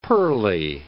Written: pearly

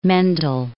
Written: Mendel

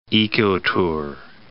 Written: ecotour

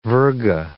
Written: virga

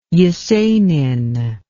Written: Yesenin

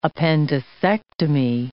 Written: appendicectomy

